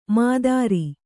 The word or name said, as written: ಮಾತರಿ